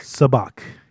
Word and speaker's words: Sabacc: No, it's it starts with an S, Sabacc. I hear people pronouncing it as Sabacc, but I'm pretty sure it's Sabacc, because I'm pretty sure they refer to it as Sabacc in Solo. If I remember correctly, they pronounce it Sabacc.